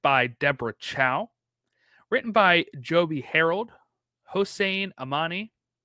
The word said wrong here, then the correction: Amini: Amini, if I said that right, sorry.